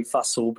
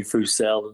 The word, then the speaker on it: Fussell: Well, there are different pronunciations. We normally say Fussell, but a lot of people call me Fussell and like that, so I'm fine.